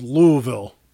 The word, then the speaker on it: Louisville: There's no, I thought it was Louis or Louis. There's no second syllable to that vowel. It's just Louisville.